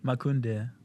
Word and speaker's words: Makunde: So how do I pronounce it so I'm respectful? – Makunde. – So I was kind of close.